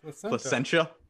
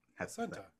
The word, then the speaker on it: Placentia: Careful with that pronunciation. That placentia. – Yeah, placentia. – Placentia. – They also have a brewery in Anaheim as well. – No. See, I'm pronouncing it like a Wisconsinite.